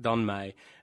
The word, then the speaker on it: Meij: So I started doing Don May, as we now know it is pronounced, Don May.